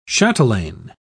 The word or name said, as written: chatelain